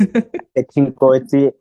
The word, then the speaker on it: Kgethi Nkotsi: It's Kgethi Nkotsi on Twitter, and then Kgethi_Nkotsi on Instagram.